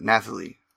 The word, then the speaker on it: Nathalie: I think it's like Natalie, but Nathalie. I hope I said it right.